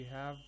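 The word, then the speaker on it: حا: And essentially, what you're supposed to do is that you have to pronounce the names of these letters. We have ha. Okay? – Yes. – So we have to say ha. Then we have meem. Okay, so yes. – We pronounce the name of the letter.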